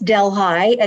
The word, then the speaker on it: Delhi: He's only in Delhi, which for people who are not from upstate New York is pronounced Delhi.